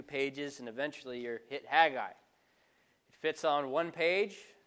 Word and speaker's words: Haggai: The prophet Haggai, or also called Haggai, both pronunciations are relatively correct. There's really not one correct one, but I like Haggai, so that's what we're going to call it.